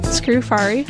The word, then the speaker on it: Scrufari: My name is Becca Scrufari. S as in Sam, C-R-U, F as in Frank, A-R-I.